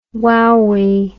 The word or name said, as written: wowee